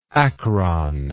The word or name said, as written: acron